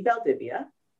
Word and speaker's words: Valdivia: LinkedIn, all as Amy Valdivia.